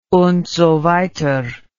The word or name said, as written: und so weiter